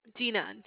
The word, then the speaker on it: Dina: Dina, spelled D as in David, I, N as in Nancy, A.